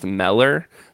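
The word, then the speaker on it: Müller: And he said, no, it should actually be pronounced Meller. But I don't know that anybody has ever called. I've never heard anybody refer to him as Mueller. George Mueller. So we're going to keep calling him George Mueller because that's what, if nothing else, we English-speaking people have done to his name.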